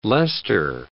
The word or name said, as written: Leicester